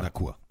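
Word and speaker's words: Nacua: Nakua.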